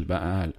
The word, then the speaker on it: Baal: Une avec à la fin un, petit familier qui est une espèce de chèvre diabolique qui s'appelle Baal. Oui, non, faut le prononcer comme ça, sinon, sinon tu captes pas la blague.